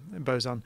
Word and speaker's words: boson: How do you say boson? Do you say boson? – Yeah, boson.